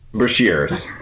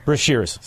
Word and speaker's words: Brashers: How do I say it? Brashers. Brashers. See, what is that? French. You're French, aren't you? It's French.